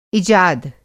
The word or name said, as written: इजाद